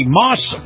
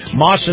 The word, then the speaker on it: Maus: And this portion of the program brought to you by our very good friends at the Moss Family Automotive Group. Moss is spelled M-A-U-S.